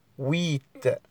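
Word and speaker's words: huit: ويت ويت في ناس كتير بتخطأ فيها بينطق بينطق حرف الاش الاش لا ينطق هو الاتش يعني الاش لا ينطق نقولش عليه ويت والكلام ده لا ويت الاش لا ينطق والحرفين اللي بعد الاش بينطقوا وي وتي ويت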